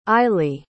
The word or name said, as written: eilley